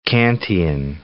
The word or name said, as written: Kantian